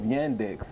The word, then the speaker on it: Yandex: When you come behind the name of Yandex, it rhymes with spandex.